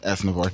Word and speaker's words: Aznavour: Aznavour? – Aznavour.